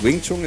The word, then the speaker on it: Wing Chun: Wing Chun, ist das richtig? Habe ich das richtig ausgesprochen? – Wing Chun ist eine der Kampfkünste, die ich jetzt seit über 35 Jahren betreibe, ja.